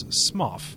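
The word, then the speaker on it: Smough: However, Go pronounces his own name Goff. Which I feel also means our Lardy Executioner's name is Smoff.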